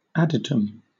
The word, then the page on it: adytum: British English